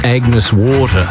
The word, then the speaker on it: Agnes Water: Heading down to Agnes Waters. – Mate, it's pronounced Agnes Water.